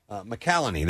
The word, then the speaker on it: McCallany: And then the last birthday is Holt McCallaway. – Who? – Pull up IMDb real quick. Holt is his name, and I recognize his picture. McCallany, that's how you say it.